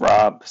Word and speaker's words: Robb: And that's Rob spelled with two B's.